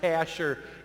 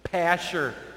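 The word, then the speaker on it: Pashhur: This guy's name is pronounced Pashur. You may as well know that. Let's say it with me, please. Pashur.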